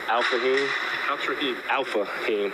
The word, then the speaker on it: Alfahiym: But she likes Al Fahim. That's what she named me. So. – What is it? – Alpha Heem. – How do you spell that? – A-L-F-A-H-I-Y-M.